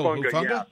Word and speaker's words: Hufanga: Oh, Hufanga.